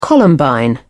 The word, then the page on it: columbine: British English